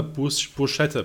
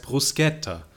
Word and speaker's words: Bruschetta: Und wir waren beim Italiener in England und dann hat der Kellner gesagt, nee, das heißt Bruschetta. Also das habe ich jetzt vielleicht auch nicht ganz hundertprozentig ausgesprochen, aber das war auf jeden Fall ein hartes K, ja, nicht kein Sch. Und dann, seitdem weiß ich, okay, SCH ist hart.